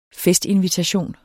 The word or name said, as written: festinvitation